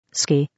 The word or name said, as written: ski